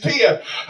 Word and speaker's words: Thea: It's spelled T-H-E-A, but she says her name's Tia.